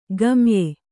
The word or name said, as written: ಗಮ್ಯ